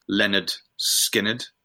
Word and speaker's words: Lynyrd Skynyrd: So Mark has selected the debut album, pronounced Lynyrd Skynyrd, from Lynyrd Skynyrd.